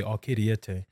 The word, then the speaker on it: Ocriete: Actually, normally what I would do is like pronounce, say, Ocriete.